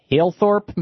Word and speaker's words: Halethorpe: Liz in Halethorpe, Maryland.